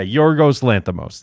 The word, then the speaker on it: Yorgos Lanthimos: Lorgos. – Lanthimos? – Yeah. Hold on. Where is his name? Yeah. Yorgos Lanthimos.